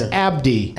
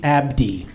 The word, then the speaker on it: Abdi: Barkhad, you got right, but it's Abdi. – Abdi.